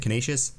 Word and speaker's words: Canisius: They only had a one game away series at Constantius. How do you say that? Canisius.